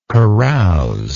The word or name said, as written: carousingly